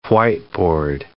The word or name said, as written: whiteboard